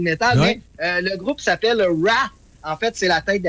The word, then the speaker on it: Wrath: Oui, la colère des dieux, de Wrath of Khan. – R-A-T-H, oui, Wrath.